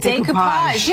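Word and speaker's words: découpage: Decoupage.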